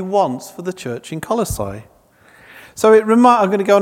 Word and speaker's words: Colossae: We had a discussion earlier in the week, Ben and I, about how you pronounce Colossae. I'm going to stick with Colossae. There may be other ways to pronounce it.